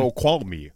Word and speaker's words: Snoqualmie: Snoqualmie, Washington. – Snoqualmie.